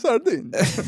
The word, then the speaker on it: Sardinia: Sardinia.